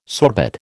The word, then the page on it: sorbet: American English